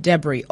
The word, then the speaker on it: debris: The word is spelled as D-E-B-R-I-S. It's pronounced debris or debris, okay?